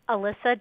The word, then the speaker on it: Alyssa: Alyssa Dweck, A-L-Y-S-S-A, Dweck, D-W-E-C-K.